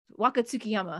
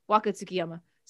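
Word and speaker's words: Waka Tsukiyama: They had Tsuki Wakayama another time. Wakatsukiyama. Am I saying her name right? Yeah. Yes. Yeah. Wakatsukiyama.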